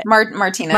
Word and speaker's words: Martinez: Martinez.